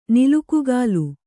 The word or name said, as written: ನಿಲುಕುಗಾಲ್